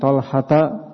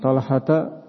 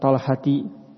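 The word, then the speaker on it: Talhata (طلحة): Kita bacanya dengan an Talhata. An Talhata ibni Abdullah. Talha. Kenapa tidak kita baca dengan an Talhati?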